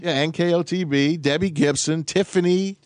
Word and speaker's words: NKOTB: I said it wrong, and you corrected me the correct way. – There you go. – I said NKTOB.